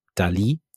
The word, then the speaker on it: DALL-E: Nämlich jemand hat nicht nur über ChatGPT, sondern auch über andere KIs, nämlich unter anderem auch bildgenerierende KIs, zum Beispiel DALL-E, also DALI, äh, ich glaube, es wird DALI ausgesprochen, ebenfalls von OpenAI produziert, hat ein Kinderbuch hergestellt.